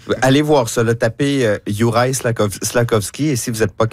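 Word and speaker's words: Slafkovský: Slakovský. – Oui, ça s'écrit comme ça se prononce.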